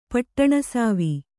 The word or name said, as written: ಪಟ್ಟಣಸಾವಿ